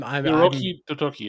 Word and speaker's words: Hiroki Totoki: I just I'm Hiroki Totoki, yeah.